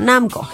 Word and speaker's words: Namco: Creado el juego de Pac-Man por el diseñador de videojuegos Toru Iwatani de la empresa Namco o Namco, ¿cómo se pronuncia? Bueno, Namco.